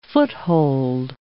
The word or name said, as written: foothold